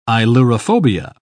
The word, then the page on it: ailurophobia: British English